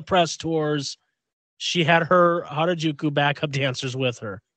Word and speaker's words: Harajuku: And so they also talked about her first fragrance collection, which and correct me if I'm wrong on how to pronounce this. Is it Harajuku? Okay, Harajuku Lovers,